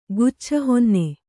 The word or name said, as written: ಗುಚ್ಛಹೊನ್ನೆ